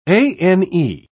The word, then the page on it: ane: British English